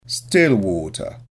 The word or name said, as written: still water